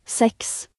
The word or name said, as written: 6